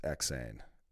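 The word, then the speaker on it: Eksayn: They, just so you know, it's like, you say it like insane, but it's Xane.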